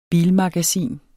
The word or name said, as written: bilmagasin